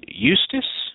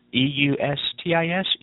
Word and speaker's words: Eustis: Let's see, uh, uh, Eustis, E U S T I S, Eustis, Florida.